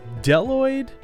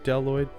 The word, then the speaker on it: Bdelloid: I'm, there's a B at the beginning of that first word, Bdelloid. – The yeah. Bdelloid. – Um, it's not pronounced. um, I would imagine it isn't. – Because it's impossible. It's not a buzeloid.